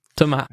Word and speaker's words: Thomas: Thomas is, okay. Is usually the French pronunciation, yeah.